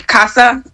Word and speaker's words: Casa: Casa, it's Trump v. Casa, is Spanish for house, and Casa is an immigration organization. So look up Trump v. Casa, spelled like C-A-S-A, same as Casa, but I just wanted to make sure I pronounced it the correct way.